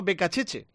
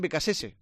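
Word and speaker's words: Beccacece: Lo pronuncian estilo italiano Becacheche. En Argentina dicen Becasese, así que ya veremos cómo le acabamos llamando.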